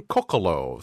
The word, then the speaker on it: Coccolo: So it is Martin Coccolo. So the stress is on the first syllable.